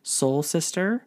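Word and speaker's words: Seoul Sister: So one of the, it's a concept from Levy Restaurants, and it's going to include Soul Sister, which is spelled S-E-O-U-L, so I'm guessing Korean-inspired food, Sip and Sonder, Gigi's Chicken Shop, and a second-story bar.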